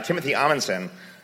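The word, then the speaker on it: Timothy Adsit: Timothy Amundsen, what are... did I pronounce that right? – Yes.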